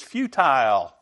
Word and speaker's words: futile: They became futile. Another way to pronounce it is futile.